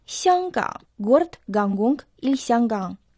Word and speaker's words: 香港: Название города Гангунг в китайском языке состоит из двух иероглифов – Сянган – Первый иероглиф «сян» произносится в первом доне, означает аромат, ароматный. Второй иероглиф «кан» произносится в третьем доне, означает залив, бухта.